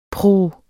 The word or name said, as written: pruh